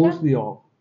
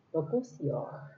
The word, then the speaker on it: Bocuse d'Or: Bocuse d'Or. – Bocuse d'Or.